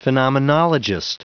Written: phenomenologist

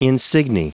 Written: insigne

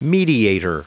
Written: mediator

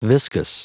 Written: viscous

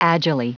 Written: agilely

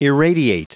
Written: irradiate

